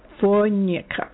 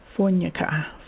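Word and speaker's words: Fojnica: F-O-J-N-I-C-A, I'm going to try to say that. Fonyika, that's it. J is pronounced twice. Fonyika.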